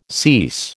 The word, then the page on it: cease: American English